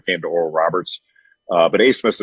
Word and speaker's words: Abmas: You remember Oral Roberts beat Ohio State a couple years ago, and they still have the same guard, Max Asmus, spelled A-B-M-A-S, and it's pronounced Asmus.